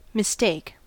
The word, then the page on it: mistake: American English